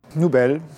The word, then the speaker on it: Noubel: So how do you say that in English? Nobel? – Nobel, just the same way, yes.